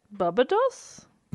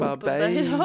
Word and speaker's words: Barbados: Barbados. – Barbados. Bubba Doss. Are we sure that it's not technically pronounced Bubba Doss? – Pretty sure.